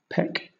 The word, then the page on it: peck: British English